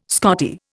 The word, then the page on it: Scottie: Indian